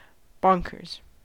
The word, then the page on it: bonkers: American English